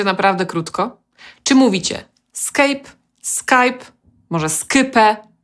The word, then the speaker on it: Skype: Po angielsku poprawna wymowa tej nazwy to Skype.